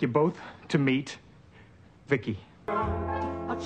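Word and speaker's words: V.I.C.I.: V-I-C-I, I, I pronounce it Vicky everybody, the host of She's a Small Wonder, a Small Wonder podcast.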